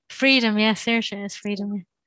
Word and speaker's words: Saorsa: I don't know if I'm pronouncing it correctly, but that's the Gaelic word, S-A-O-R-S-A. – Oh, yeah, Sirsa, I think. Is it Sirsa? – Sirsa.